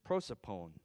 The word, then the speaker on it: prosopon: So now if we jump over to the New Testament, in the New Testament, the Greek word is prosopon, and I'm going to spell that. P-R-O-S-O-P-O-N.